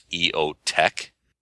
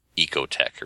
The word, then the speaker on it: EOTech: That's pronounced EOTech. EOTech, as opposed to Ecotech or something.